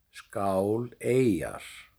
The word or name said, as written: Skáleyjar